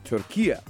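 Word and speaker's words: Türkiye: So, based off of them, we're saying it wrong. So it should be said Turkiye.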